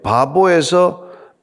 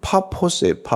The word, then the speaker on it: 파포스: 그 동행하는 사람들과 함께 바보에서 배를 타고 바보 발음이 조 이상하긴 한데 이게 원래 이름은 파포스예 파포스라는 곳인데 가면 한국 아름다운 곳인데 여기서 이제 배를 타고 이제 올라가는 것이죠.